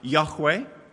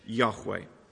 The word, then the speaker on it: Yahweh: The Hebrew is pronounced something like Yahweh. I'm not a Hebrew scholar, but that is how it's pronounced.